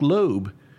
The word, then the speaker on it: Loeb: She was engaged to a businessman named Nick Loeb. I think that's right, L-O-E-B, Loeb.